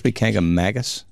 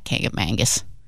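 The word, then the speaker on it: Kancamagus: The Kangamangus one. Is it supposed to be Kangamangus? – Mongus. – I think it's Mongus. – Kangamongus. Kangamangus.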